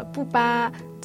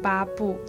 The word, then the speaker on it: 布巴: 下一个就是我们的布巴，这边要注意哦，很多人都会把它念成巴布。